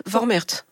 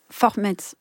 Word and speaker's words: Vormärz: Vormärz. – Vormärz.